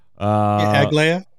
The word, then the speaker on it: Aglaia: Aglaia is the name of it. If I'm pronouncing that right, like who knows anymore? – Aglaia. Yeah. – That's the way I said it.